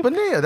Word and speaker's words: Bonilla: Bonilla. That's how you pronounce it. – All right. – When it's two L's, it's Spanish. It's like a Y.